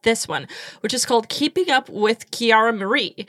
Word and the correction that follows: Kiara Mia: Wait, am I butchering the name? – Kiara Mia.